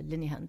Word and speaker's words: Linehan: Därav att man uttalar henne Linehan, det stavas L-I-N-E-H-A-N.